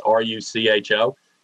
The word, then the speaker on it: Rucho: I think I am pronouncing this correct, RUCO, it's RUCHO, versus Common Cause.